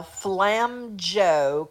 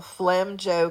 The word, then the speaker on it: FlamJeaux: That's Margot Brignac from one of the cleverest named businesses I've heard in a while, Flam Joe Coffee. That is spelled FlamJeaux. She hits them all. Flam Joe Coffee.